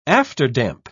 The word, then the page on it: afterdamp: British English